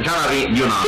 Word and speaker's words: Jean-Henri Dunant: Oggi parliamo di Jean-Henri Dunant, pronuncio bene? Jean-Henri Dunant. – Sì, sì.